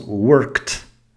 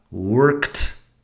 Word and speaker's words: worked: Luego cuando pronunciamos el pasado, después del sonido de una K, la forma con ed suena como si fuera una T y nada más. Así tenemos worked and walked. Worked, walked.